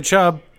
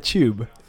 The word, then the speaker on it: Chubb: You mispronounced Nick Chubb. – I said two syllables. – I said Nick. – But you didn't say Chubb. – I said tube.